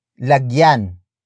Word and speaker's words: lagyan: Word for word it's, lagyan, ng, kumot, ang, ibabaw, ng, kama. Lagyan nang, kumot ang ibabaw ng kama. Lagyan ng kumot ang ibabaw ng kama. The first word is lagyan.